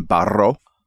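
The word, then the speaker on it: Barro: Josh Barrow, who I think that we should just pronounce his name Barrow from now on. Just if you have the double R, just go for it.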